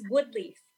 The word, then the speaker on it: Waleef: It's Waleef. – I thought it's spelled differently. – The IEF really throws people off.